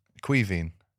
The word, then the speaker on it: Caoimhín: Caoimhín.